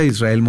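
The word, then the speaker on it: Izrael: Israel con Z, también se escribe mi nombre con Z, Israel Moreno en todos lados.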